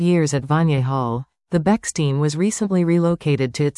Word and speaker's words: Bechstein: The Bechstein, pronounced Bechstein,